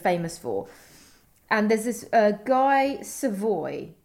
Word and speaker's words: Savoy: But it's S-A-V-O-Y. – Oh, bah oui, mais ça se prononce Guy Savoy.